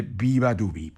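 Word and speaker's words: beabadoobee: Ay, bueno, no me extraña que se buscara un nombre artístico todavía más complicado, si acaso, porque se escribe Beabadoobe y se dice Viva Dubi.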